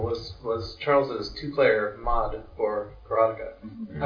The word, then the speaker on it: Karateka: I was hearing people call him Mechner, and they were correcting each other on Karateka. It's pronounced Karateka.